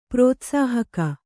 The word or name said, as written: ಪ್ರೋತ್ಸಾಹಕ